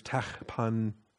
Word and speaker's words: Tahpanhes: In Hebrew, it's Tahpanhes.